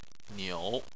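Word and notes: niu: tone 3

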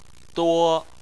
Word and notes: duo: 1

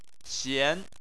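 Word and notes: xian: xian2; tone 2

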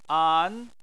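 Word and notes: an: tone 2

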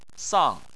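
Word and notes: sang: tone 4

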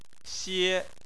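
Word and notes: xie: xie1; tone 1

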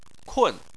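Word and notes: kun: tone 4

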